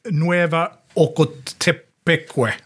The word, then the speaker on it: Nueva Ocotepeque: 16 juli, dagen efter, så sker liksom det enda större slaget när El Salvador som är når samhället Nueva Ocotepeque.